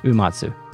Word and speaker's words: Uematsu: Nobuo Uematsu. Am I saying that right? – Uematsu. – Nobuo Uematsu. I always delete a syllable there.